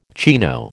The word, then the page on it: chino: American English